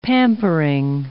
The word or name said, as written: pampering